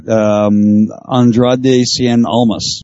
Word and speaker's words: Andrade Cien Almas: Andrade Cien Almas.